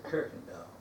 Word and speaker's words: Kuykendall: A Dutch name means keeper of the chicken, but they pronounce it Kuykendall. – Oh, okay. – I mean, she was called Kuykendall, but the people that know always, almost routinely said Kuykendall.